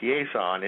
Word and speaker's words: Chiasson: These names in Louisiana, you're finding out, you know, you got to put the French to them. But his last name was Quezon. It's spelled C-H-I-A-S-S-O-N.